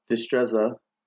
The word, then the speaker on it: Destreza: So I brought you on today to talk about Destreza. Am I saying that right? – Yeah.